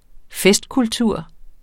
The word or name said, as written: festkultur